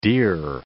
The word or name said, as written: dear